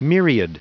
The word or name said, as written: myriad